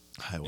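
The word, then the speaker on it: Hahuey: Highway. I don't ever know if I'm saying it right. It's H A H U E Y. – Highway.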